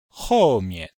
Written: hou4mian5

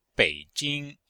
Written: bei3jing1

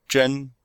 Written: zhen1

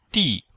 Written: di4

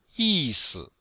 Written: yi4si5

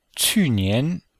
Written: qu4nian2

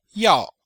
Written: yao4

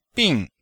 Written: bing4

